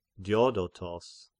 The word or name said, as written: Diodotos